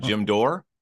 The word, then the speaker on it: Dore: Jim Dore? – Yeah. – Is that how you pronounce his last name? – Yeah.